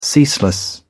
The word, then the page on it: ceaseless: British English